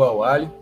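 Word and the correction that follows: Al-Ahli: no caso, o Al-Hali, né, a pronúncia,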